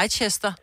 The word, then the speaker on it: Leicester: Det staves jo også sådan noget Leicester, jo, jo. men bliver bare udtalt Leicester.